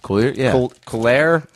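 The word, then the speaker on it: Cuyler: This guy did Coolier Smith. Is that the artist? I don't know how to pronounce the name. It's C-U-Y-L-E-R. – Coolier?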